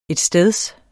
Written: etsteds

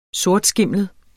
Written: sortskimlet

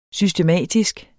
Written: SYSTEMATISKE

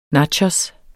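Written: nabovinkel

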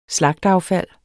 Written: slagteaffald

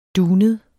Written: dunet

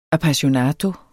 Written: appassionato

